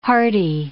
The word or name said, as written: hearty